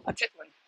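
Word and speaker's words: Atitlán: Atitlan. It sounds like a tit. Atitlan.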